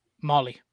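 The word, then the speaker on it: Mahle: It's I'm gonna butcher his last name, but Tyler Mao. – Molly. – Molly, there you go.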